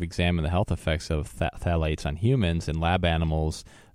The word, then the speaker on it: phthalates: It's spelled P-H-T-A-T-H-A-L-A-T-E-S. So it's spelled kind of weird, but that's how they pronounce it, phthalates.